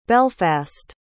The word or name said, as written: belfast